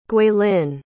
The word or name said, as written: guilin